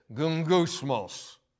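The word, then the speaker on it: gungusmos (γογγυσμός): The Hebrew word for murmuring is pronounced something like this: gungusmos.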